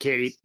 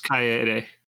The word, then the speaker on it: Kaede: I pronounce, I've always pronounced it Katie, but Ka- Kaede. – It's Kaede.